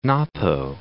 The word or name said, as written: Napo